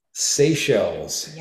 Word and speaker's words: Seychelles: Seychelles. Yes. Seychelles, seashells.